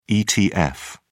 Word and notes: etf: British English

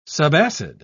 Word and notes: subacid: British English

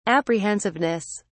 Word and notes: apprehensiveness: British English

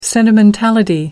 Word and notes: sentimentality: British English

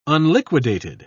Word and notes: unliquidated: British English

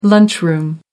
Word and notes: lunchroom: American English

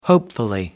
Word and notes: hopefully: American English

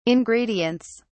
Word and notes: ingredients: British English

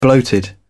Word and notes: bloated: British English

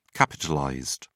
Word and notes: capitalized: British English

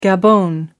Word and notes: gabon: American English

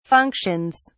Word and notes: functions: British English

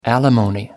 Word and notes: alimony: American English

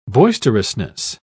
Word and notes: boisterousness: British English

